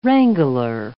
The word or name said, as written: wrangler